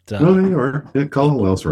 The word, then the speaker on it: Kalalau: Kalalau. I'm sorry, I'm not pronouncing that correctly, but Kalalau's right.